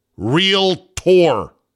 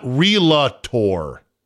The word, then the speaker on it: Realtor: Realtor is two syllables. Realtor, not Relator.